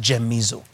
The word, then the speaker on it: gemizo: Okay, it's actually pronounced gemizo, G-E-M-I-Z-O, gemisate, to feel.